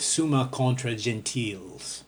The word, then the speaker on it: Summa Contra Gentiles: Thomas believed that the existence of God can be demonstrated briefly in his Summa Theologiae and more extensively in the Summa Contra Gentiles. I'm pronouncing that like French, not Latin.